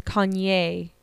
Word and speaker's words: Kanye: Or as they would say on Keeping Up with the Kardashians, Kanye, accent on the E.